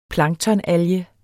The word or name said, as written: planktonalge